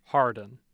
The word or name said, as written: hardin